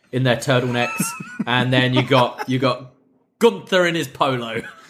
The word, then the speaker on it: Gunther: The announcer goes, like, Fabian Aichner, Marcel Bartel, and Gunther, and he goes, nein. It's pronounced Gunther.